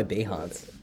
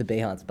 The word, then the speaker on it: Behance: Every time I try to log into Behance. By the way, I pronounce it Behance.